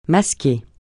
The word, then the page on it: masquer: francais